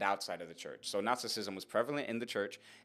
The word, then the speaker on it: Gnosticism: Gnosticism. Now, Gnosticism uh, it starts off with a with a silent G, so it's a very weird word to spell and a very a very weird word to write.